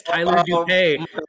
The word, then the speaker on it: Tyler Doupe: We have, and correct me if I'm saying your last name wrong, Tyler. We have Tyler Doupe? – That can't be right. You got it right. It's Tyler Doupe.